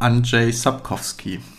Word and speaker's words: Andrzej Sapkowski: Okay, äh, ich bin mir jetzt auch nicht sicher, ob es 100% korrekt ist, aber Andrzej Sapkowski.